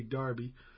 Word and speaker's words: Derby: We had Sophie Sverum and Christopher Gregory out of, now it's spelled, we'd spell it Derby, huh? But they say Darby.